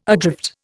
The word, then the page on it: adrift: Indian